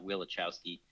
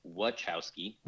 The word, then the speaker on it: Wachowski: First of all, we're saying their name wrong. It's not Wachowski. It's Wachowski.